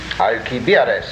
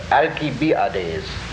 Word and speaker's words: Alcibiades: When Alcibiades... – Alcibiades. That's a great pronunciation. Let's stick to that. Alcibiades.